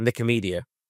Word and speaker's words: Nicomedia: Nicomedia.